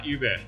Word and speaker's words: Uber: 所以正确来说，这个其实是发 Uber。 – Uber。